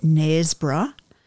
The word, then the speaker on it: Knaresborough: And we are stopping at Knaresborough.